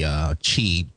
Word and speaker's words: Qi: And one is kind of an industry standard, the Qi, and it's QI, but they pronounce it Qi, wireless charging standard, which is what Nokia was using.